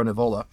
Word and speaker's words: Nivola: Alessandro Nivola, which is a really funny mispronunciation because he's actually was born in like Massachusetts. So it's Alessandro Nivola.